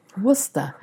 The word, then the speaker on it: Worcester: Let's talk about Worcester. – Worcester.